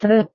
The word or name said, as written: truk